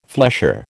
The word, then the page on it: flesher: American English